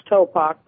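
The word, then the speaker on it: Topock: Actually, it's pronounced Topok, but never mind.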